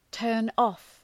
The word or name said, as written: turn off